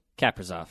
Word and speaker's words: Kaprizov: It's time to anxiously await the arrival of, am I saying it right, Kaprizov? – Kirill Kaprizov.